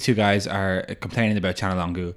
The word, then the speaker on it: Çalhanoğlu: hey, it's pronounced Çalhanoğlu. And they're like, what? And he's like, listen, repeat after me. Chan. And they're like, Chan. Longu. Longu. Çalhanoğlu.